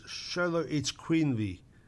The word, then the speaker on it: Xoloitzcuintli: It's spelt X O L O I T Z C U I N T L I. If you look up, it says Sholo Eats Queenly.